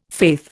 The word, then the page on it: faith: Indian